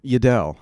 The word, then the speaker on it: Yaddaw: Yaddaw.